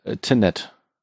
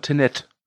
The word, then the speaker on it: Tenet: Ich glaube einfach Tenet. – Tenet? – Ja. – Ja, hinten wie von vorne, ne? Tenet.